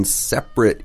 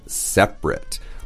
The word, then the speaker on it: separate: When separate is an adjective, like here, it's pronounced separate. But when the waitress is doing the math, she is separating. – Oh, okay, 也可以当动词 Right, so as a verb, it's separate. As an adjective, it's separate.